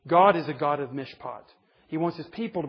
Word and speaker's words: mishpat: Here's the word mishpat, is the way you pronounce it.